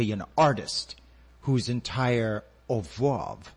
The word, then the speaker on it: oeuvre: It's pronounce it again. – Oeuvre. It's a French word for entire body of work. – Oeuvre. Oeuvre? – Yeah. Good.